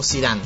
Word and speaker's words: Zidane: ¿Zidane o Zidane? ¿Cómo se dice? – Zidane está bien.